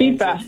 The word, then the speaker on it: FIFA: Which is it? – FIFA. – FIFA? FIFA. Thank you. I always want to say it the wrong way.